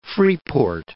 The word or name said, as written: Freeport